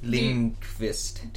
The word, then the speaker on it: Lindqvist: Lindqvist. – Do you pronounce the Q? – Yeah, I think so.